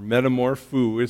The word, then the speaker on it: metamorpho: Now when Paul uses the phrase in that verse that says being transformed, it's a Greek word metamorpho, or metamorpho is how we probably would say it, morpho. And that sounds familiar, right? Sounds like the word metamorphosis.